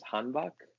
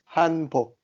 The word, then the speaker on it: hanbok: hanbok. Am I saying that right? Hanbok, like a dress. Hanbok. Hanbok. Hanbok. Yep, that's right.